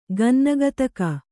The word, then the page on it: ಗನ್ನಗತಕ: Kannada